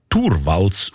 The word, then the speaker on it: Torvalds: Der Linus Torvalds, eigentlich Torvalds ausgesprochen, damals Informatikstudent in Helsinki, er hat die wertvollste Software von der Welt namens Linux einfach verschenkt.